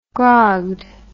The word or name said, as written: grogged